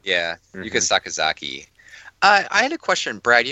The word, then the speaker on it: Yuka Sakazaki: So Riho defeated Nyla Rose and Yuka Sakazaki.